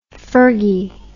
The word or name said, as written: fergie